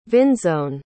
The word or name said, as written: vinsone